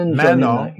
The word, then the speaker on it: Manon: Manon, don't want to pronounce the N.